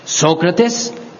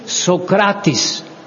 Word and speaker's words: Socrates: Once he told us, Socrates is not the right pronunciation. The right pronunciation is Socrates. We were all taken aghast, because this is indeed the right pronunciation in Greek.